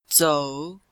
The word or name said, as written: zou3